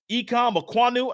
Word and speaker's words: Ikem Ekwonu: Ikem Ekwonu, uh, that might be the first time I've said his name correctly, went number six to the Panthers.